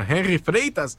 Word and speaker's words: Henry Freitas: Henry Freitas. Is that how you say his name? – That's correct.